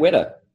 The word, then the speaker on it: Weta: You just said it right, mate. Weta. – Yes, perfect. Cool. I've read it. Weta, I've read Weta, so I'm glad that I nailed it. – Yep, Weta. Just think of wet and then a little bit more.